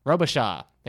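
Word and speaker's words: Robicheaux: Robichaud. – Thank you. – It's about Dave Robichaud.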